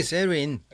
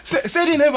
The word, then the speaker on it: 사린: 솔직히 세르윈. – 세르윈. – 역시 텍사스 출신은 좀 달라. 발음이. 세린 해봐요, 함장님.